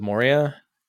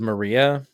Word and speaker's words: Moria: She pronounced it Minds of Maria. – And we were very disappointed. – At a Geeks Who Drink event? – Yeah, that's what it was. – And I was like, well, I'm done with this place. this is how you... Geeks Who Drink? You don't know how to pronounce the word Moria?